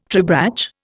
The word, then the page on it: tribrach: Indian